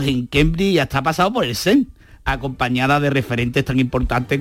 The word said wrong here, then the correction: CERN: Cuenta que es el CERN.